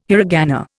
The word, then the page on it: hiragana: Indian